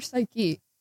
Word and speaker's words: Psyche: S is not even the first letter. It's P. P-S-Y-C-H-E. Is that Psyche or Psyche? – I think it's Psyche.